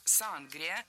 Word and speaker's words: сангрия: Я сама слышала чаще вариант сангрия, а филологи рекомендуют говорить сангрия с ударением на втором слоге.